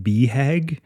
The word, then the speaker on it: BHAG: Especially even the acronym BHAG, which apparently is supposed to be pronounced BHAG, is also kind of revolting.